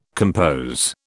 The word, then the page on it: compose: British English